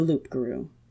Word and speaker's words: loup-garou: Then there are the last, the most dangerous kind, the loup-garou. Which, in French, I'm pretty sure wouldn't have a P sound, but James Marsters pronounces it with a P, so will I.